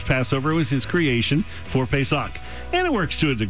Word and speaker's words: Pesach: And sixth is Pesach. P-E-S-A-C-H.